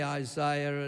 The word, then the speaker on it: Isaiah: Yeah, I still say Isaiah and not the way you Americans say it. It's proper English.